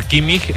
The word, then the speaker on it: Kimmich: El problema es que la pronunciación de la CH en Alemania es como una J. Es decir, Kimmich es como Kimmich.